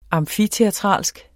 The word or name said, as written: amfiteatralsk